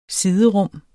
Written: siderum

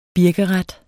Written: birkeret

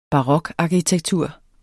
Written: barokarkitektur

